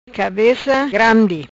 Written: Cabeca grandi

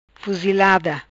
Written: Fuzilada

